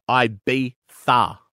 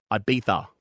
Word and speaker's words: Ibiza: No, that is not correct. That's not how you say that. It is actually pronounced Ibiza. Ibiza. – There's no tha in that. – I'm telling you right now, it's Ibiza.